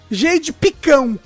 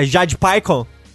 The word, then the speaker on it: Jade Picon: Eu só, todo mundo só escreve Jade Picão.